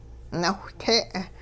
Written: nawhte'

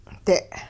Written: de'

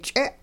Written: ch'e

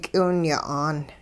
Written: k'iwinya'aan

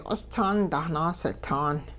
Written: q'ostaan dahnaasitaan

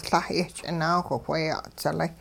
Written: La' yehch'inaawh wheya'dzilay

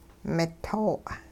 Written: mito'

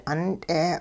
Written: 'unt'e

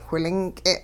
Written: whilink'e

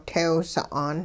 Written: tew sa'an

